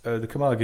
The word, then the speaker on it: Camargue: I think I'm going to say this wrong, but it's in Camargue. Is that the area? – Well, that's one of the areas. That's certainly where it started. It was the Camargue is also on the Mediterranean, France, but close to Marseille, just past Marseille.